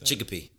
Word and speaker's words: Chicopee: Chicopee.